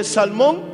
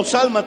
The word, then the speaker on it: Salmón: Vos era hijo de Salmón, que se había casado con Raab. Y Salmón, o Salma también se pronuncia, tuvieron a un hijo llamado Vos.